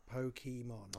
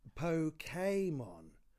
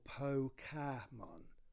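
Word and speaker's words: Pokémon: It says here that neither the Japanese nor Americans pronounce it Pokemon. – Oh, for Christ's sake, we're not on this again. – The true pronunciation is Pokemon or Pokemon.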